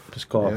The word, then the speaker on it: Pskov: Pskov, yes.